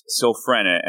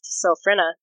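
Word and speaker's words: Sylphrena: So it's Silfrena. And unless I get a word of Brandon saying otherwise, I'm going to pronounce it Silfrena. It uh, is Tori? in fact, pronounced Silfrena.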